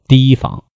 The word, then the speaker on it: 提防: 子牙屈着腰搓面，不曾提防，写作提防，提起来的提，应该读作提防。